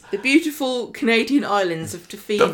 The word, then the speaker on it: Tofino: it literally, the only word on the whole postcard was Tofino.